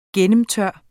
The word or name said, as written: gennemtør